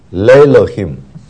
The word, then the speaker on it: Elohim: 所以你看到 Elohim，然后 to God， Elohim 变成长母音，本来是 go 来的短母音的，但是 Elohim。